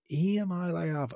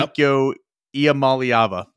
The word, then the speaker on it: Iamaleava: Iamaliava.